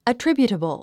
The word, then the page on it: attributable: ətríbjutəbl; アトリビュータボゥ